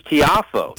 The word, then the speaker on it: Tiafoe: I don't know much about this Francis Tiafoe. Is that how you say it? – Francis Tiafoe.